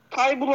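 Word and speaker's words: Thybulle: Tybull diye mi okunuyor? – Tabii. – Tybull alınabilir.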